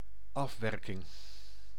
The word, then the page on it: afwerking: NL; IPA: /ˈɑf.wɛr.kɪŋ/; Dutch